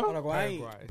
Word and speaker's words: Paraguay: How do you pronounce it? – Paraguay.